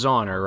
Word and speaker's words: Zauner: By Michelle Zauner. It is Zauner, right? – Yeah.